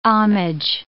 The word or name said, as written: homage